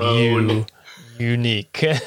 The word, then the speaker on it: Younique: For example, the cosmetic MLM Unique, which is spelled in the dumbest way. It's spelled Y-O-U-N-I-Q-U-E. So it's like you, Unique.